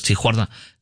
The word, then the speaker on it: Tijuana: You know, actually, I'm sure he doesn't pronounce it like a Hispanic does. Tijuana.